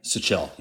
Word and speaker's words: Sichel: And The Quiet Americans chronicles the lives of these four spies Frank Wisner, Michael Burke, Peter Sech- uh, Sichel, and Edward Lansdale. Am I saying that right? – Sichel, yeah.